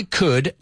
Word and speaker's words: could: If I could, la L muda, no se pronuncia esa L para nada.